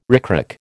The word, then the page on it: ricrac: American English